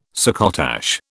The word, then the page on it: succotash: British English